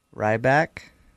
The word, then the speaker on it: Ryback: Ryback. – Can you spell that for me? – R-Y-B-A-C-K.